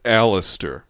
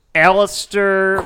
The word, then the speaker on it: Aleister: It's pronounced Alistair. – Alistair.